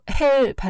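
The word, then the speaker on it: Hel: 바로 헬 반도입니다.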